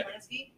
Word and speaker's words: Polanski: Polanski? – Yeah.